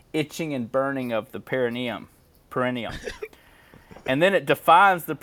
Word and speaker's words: perineum: Perineum.